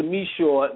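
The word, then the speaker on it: Micheaux: And Oscar Meshaw was the Spike Lee of his day.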